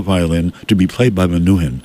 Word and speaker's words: Menuhin: His name is Yehudi Menuhin, M-E-N, that's M-E-N-U-H-I-N, okay, Menuhin, or Menuhin. Menuhin is probably the correct pronunciation.